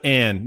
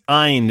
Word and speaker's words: Ayn: It's not Ayn. That's insulting. It's Ayn. It rhymes with nine.